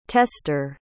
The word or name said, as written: testor